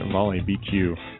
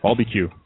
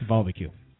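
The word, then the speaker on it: Volbeat: Talk about the Volley BQ. Volbeq. Good. Yeah, let's say it right there. – Volbeq. – Volbeq.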